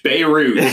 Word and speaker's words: Beirut: You get like a French accent. – Beirut. – Just say it in English. Beirut.